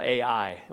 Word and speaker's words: Ai: That's not artificial intelligence. It was called Ai. It was the way you pronounce it.